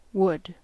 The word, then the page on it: would: /wʊd/